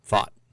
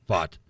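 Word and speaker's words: Pfaadt: I'm going with the Fat. That's what it's pronounced, right? – Fought. – Fought.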